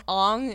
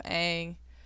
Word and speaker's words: Aang: Well, I started calling him Ong instead of Aang, and I think that's where I was like, nope.